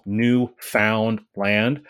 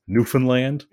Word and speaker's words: Newfoundland: It's it's spelled Newfound Land, mm-hmm. but it's definitely pronounced Newfoundland.